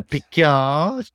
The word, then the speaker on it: Picard: The Picard doesn't mm-hmm.